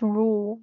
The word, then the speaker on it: Ruel: I don't really know even how to pronounce the name. Ruel. – I think it's Rule.